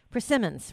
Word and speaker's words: persimmons: I know that the the permisons uh, or prism prism. – How do you say it? – persimmons, right? Persimmons. Persimmons.